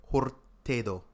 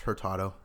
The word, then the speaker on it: Hurtado: Hurtado. – Hurtado. – No, it's Hurtado.